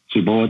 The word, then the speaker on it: Cebu: Cebu, I think is how you pronounce it.